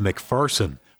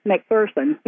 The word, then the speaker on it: McPherson: And his name is pronounced McPherson, according to Brenda Stultz, curator of his boyhood home in Clyde, Ohio. – There's no fear in McPherson. You know, that's why we're supposed to pronounce it with the F-U-R sound rather than the F-E-A-R sound.